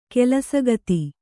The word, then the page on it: ಕೆಲಸಗಿತ್ತಿ: kelasagitti